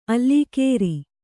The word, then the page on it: ಅಲ್ಲೀಕೇರಿ: allīkēri